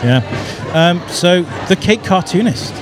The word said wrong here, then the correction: caketoonist: It's the caketoonist.